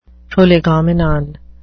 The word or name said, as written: prolegomenon